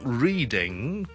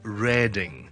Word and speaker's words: Reading: 第一个要特别留意嘅咧就系 reading， it's not reading， but it's reading， reading， reading， reading，中文叫做雷丁嘅一个地方。